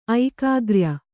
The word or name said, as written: ಐಕಾಗ್ರ್ಯ